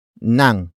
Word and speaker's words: ng: The next word is nang, which is spelled as ng.